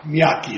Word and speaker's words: Miyake: Miyake, Your Honor. – Miyake. – Rhymes with Mr. Miyake.